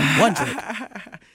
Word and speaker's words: Wandrick: Wandrick.